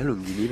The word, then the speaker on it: Lunghini: Je le prononce bien, Lunghini, parce que... – Oui, à chaque fois, tout le monde me demande.